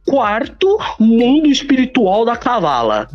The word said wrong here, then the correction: cabala: Da cavala, ótima. Da cabala, né?